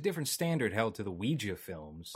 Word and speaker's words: Ouija: Ouija, is that how you pronounce it? The Ouija board? – Ouija, movie? Yeah, yeah.